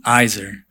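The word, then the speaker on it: Izer (עזר): So God created the first woman as in, it's spelled easy, E-R, but you pronounce it Izer.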